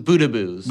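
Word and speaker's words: Boudebouz: How do you say his name again? Babadouz or Badabouz? – Boudabouz.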